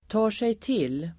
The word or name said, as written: tar sig till